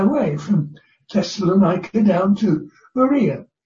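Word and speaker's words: Berea (Βέροια): And so he went from there to what is now pronounced and called in Greece, Berea. B's are pronounced as V's.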